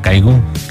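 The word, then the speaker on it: Kygo: Bueno, Caigu, pronunciación, con Conrad Sewell, esta canción que es Firestone, dentro del disco Cloud Nine, primer álbum de estudio, primer sencillo.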